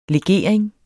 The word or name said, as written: LEGERINGEN